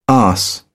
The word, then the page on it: arse: British English